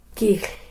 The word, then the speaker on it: kilh: Gish. – Yeah, we spell it K-I-L-H, gish. – Gish. – Not a pop, though.